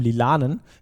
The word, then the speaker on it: lilanen: Im Lilanen. Betonungsproblem.